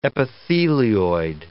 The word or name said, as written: epithelioid